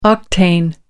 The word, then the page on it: octane: American English